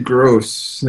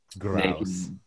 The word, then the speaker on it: Grouse: Naked Gross. Grouse? How do you pronounce this? I'm mispronouncing this on air. – Grouse.